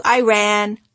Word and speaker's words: Iran: Often kids tried to be funny by chanting, "I ran to Iran, I ran to Iran." The correct pronunciation, I always informed them, is Iran. "I ran" is a sentence, I told them, as in, "I ran away from my geography lesson."